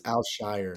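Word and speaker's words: Al-Shaair: Also saw that Al Shire, how do you say his name? – I think it's Al Shire.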